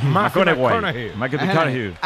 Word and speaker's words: McConaughey: Matthew McConaughey. – Sí. – Hay que ir corrigiendo la pronunciación. – McConaughey.